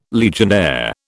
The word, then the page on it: Legionnaire: British English